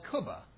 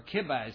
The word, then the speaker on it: kubba: There's an Iraqi dish called kubba, or kibba as some pronounce it.